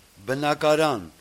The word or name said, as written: Bnakaran